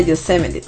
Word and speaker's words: Yosemite: Y bueno, pues sí, este fenómeno se encuentra en Yosemite, se escribe, pero se pronuncia Yosemite.